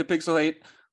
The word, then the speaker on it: Pixel Hate: Welcome back to Pixel Hate, where the H is silent, but the hate is very real.